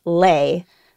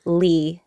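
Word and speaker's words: Le: So the traditional pronunciation of the last name L-E mm-hmm. is Lay, but I'm used to calling myself Lee, L-E,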